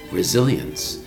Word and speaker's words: resilience: R-E-S-I-L-I-N-C-E. Resilience. Resilience. Even though there's no Z in the word, it's, the S is pronounced with a Z sound. The word is resilience. R-E-S-I-L-I-N-C-E.